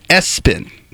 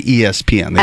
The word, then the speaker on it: ESPN: However, the family pronounces it Espen. They don't say ESPN. They Espen. – Say Espen.